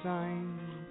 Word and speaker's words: syne: All right, that was Auld Lang Syne, which is a Scottish pronunciation. As something with the S.